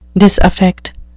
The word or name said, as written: disaffect